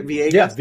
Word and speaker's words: Villegas: is Camilo Villegas. Is that I'm saying his name right? – Villegas, technically.